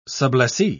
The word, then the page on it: sublessee: British English